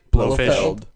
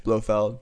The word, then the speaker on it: Blofeld: Blofeld. – Blofish. – Blofish. Blofeld.